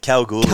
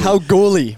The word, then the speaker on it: Kalgoorlie: How do we pronounce it? – Kalgoorlie. – Kalgoorlie.